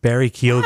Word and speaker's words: Barry Keoghan: Barry Keoghan. – Wow, did you say it right? I'm sorry, I doubted you.